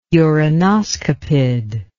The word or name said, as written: uranoscopid